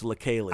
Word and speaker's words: Leikeli47: L-E-I-K-E-L-I-4-7. Okay. It's pronounced really different from how it looks. It looks like it should be like Lakely, but it's Lakely.